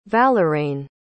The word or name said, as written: valeraine